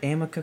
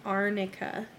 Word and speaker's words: arnica: But, like, Amber's lawyer is up there going, do you know what Amica cream is? Okay, so first of all, she pronounced that wrong, right? – Yeah, it's not Amica, it's Arnica.